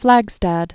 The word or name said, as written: Flagstad